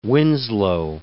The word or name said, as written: Winslow